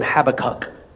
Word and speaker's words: Habakkuk: There's a prophet in the Old Testament named Habakkuk. Some people pronounce him Habakkuk. Either way, it's a funny name.